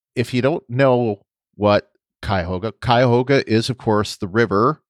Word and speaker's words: Cuyahoga: Lieutenant Willa mispronounced the name of the USS Cuyahoga. She said something like Cuyahoga or something. And of course, that is the Cuyahoga.